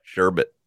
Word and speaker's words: sherbet: So there's no R at the end of bit. – Well, there's no R at the end of sherbet.